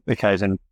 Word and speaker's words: Kaizen: And I think, you know, you use a Kaizen. Am I saying that right? Kaizen? – Yeah. We think of it from a continuous improvement perspective, which is the Kaizen principle that, Kaizen.